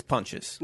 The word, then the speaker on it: Pontius: No, it's Pontius.